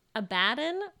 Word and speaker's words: Abaddon: Abaddon, pronounced like Aladdin, meaning doom, a place of destruction, and it also refers to the angel that rules over the abyss.